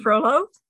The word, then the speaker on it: Frollo: And when he was threatened by Froyo, I hope that's how you pronounce his name. – Frollo? – Frollo. I keep saying Froyo because I thought that it was like the Spanish way.